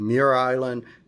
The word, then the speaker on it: Muir Island: I always called it Murr Island, but I've also heard it called Muir Island. But, you know, that works. Either one of them works for me.